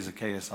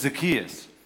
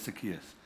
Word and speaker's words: Zacchaeus: You know, my Jersey accent wants me to say Zacchaeus all the time, but it's Zacchaeus. And on Google, one of the pronunciations says, say Zacchaeus, the other one says Zacchaeus. So I did my homework, and it's Zacchaeus.